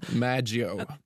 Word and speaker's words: Maggio: At det uttales Maggio. – Ja, Maggio. Veronica Maggio.